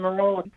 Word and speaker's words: Marolla: This is Austin Marolla.